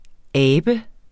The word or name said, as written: Abet